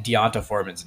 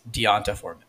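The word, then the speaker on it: D'Onta Foreman: Also, you're saying Deonta Foreman's name wrong. – Dante. Is it not Deonta? – It's Deonta Foreman.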